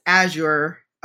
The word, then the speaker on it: Azure: And if Microsoft then has Azure, I know I probably say it wrong every single time.